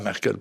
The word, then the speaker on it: Merkel: Angela Merkel, pour le prononcer comme il faut,